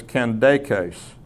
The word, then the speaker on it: Candace: The second thing I want to clarify about this passage is that the Nubian queen mentioned in verse 27, the Greek pronunciation of her name is Candaces, which suggests an English pronunciation of Candace, Candace, not Candace.